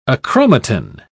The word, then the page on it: achromatin: British English